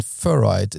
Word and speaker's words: Furoid: And Furoid is how I would pronounce it, because it's fur on steroids in a way, I guess.